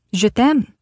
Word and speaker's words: Je t'aime: Je t'aime. That's how you say it, right? Elodie laughed. – Yes, that's perfect.